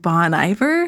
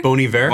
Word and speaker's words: Bon Iver: Is it like Bon Iver? – Bon Iver? – Bon Iver. – Bon Iver. – Okay. – Yeah, he's the band, Justin Vernon's the artist. Oh, yeah. Bon Iver is.